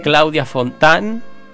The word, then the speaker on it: Claudia Fontán: Claudia Fontán, ¿lo dije bien? ¿La Bunda? – Sí, sí.